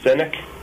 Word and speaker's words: Sinek: Sinek. I'm not sure if I'm pronouncing his name right. Yeah, you are.